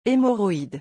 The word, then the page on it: hemorroides: French